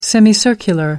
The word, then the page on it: semicircular: British English